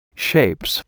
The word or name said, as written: shapes